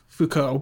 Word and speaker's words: Foucault: How do you pronounce it again? Foucault? – Foucault, but...